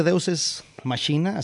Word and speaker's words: Deus Ex Machina: Oye, hablemos de Deus Ex Machina. Así me dijo el violinista que se pronunciaba.